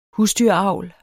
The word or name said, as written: husdyravl